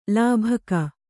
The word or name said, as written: ಲೋಭಕ